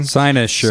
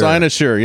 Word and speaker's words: Cynosure: It's did I say it wrong? – Cynosure. – Cynosure. Yeah, there we go.